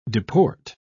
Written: deport

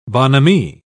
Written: bonhomie

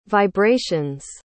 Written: vibrations

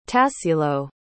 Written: tassilo

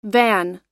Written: vin

